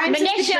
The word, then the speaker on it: Manisha: I'm your host, Barbara Ginty, and I'm also a CFP, and I am thrilled to have our expert guest here, Manisha Thakor. Did I say your name right? I'm terrible at pronunciation. – Not to worry. Manisha. – Manisha. Oh my God. Rhymes with Patricia.